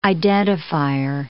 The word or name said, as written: identifier